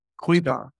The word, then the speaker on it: Kuiper: I also now know it's pronounced Kuiper.